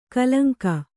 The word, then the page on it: ಕಲಂಕ: kalaŋka